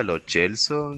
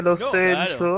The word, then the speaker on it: Lo Celso: Momento, ¿se pronuncia lo Chelso? Yo le decía lo Celso.